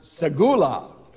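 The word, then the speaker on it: segula (סגולה): In Hebrew, it's pronounced segula.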